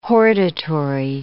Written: hortatory

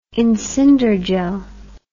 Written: incinderjell

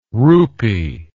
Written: roupy

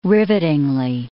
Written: rivetingly

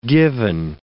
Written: given

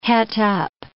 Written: het up

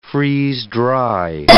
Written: freeze-dry

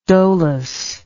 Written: dolus

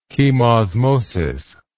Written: chemosmosis

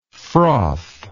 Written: frother

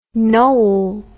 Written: Knole

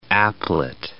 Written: applet

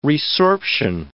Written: resorption

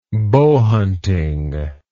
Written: bowhunting

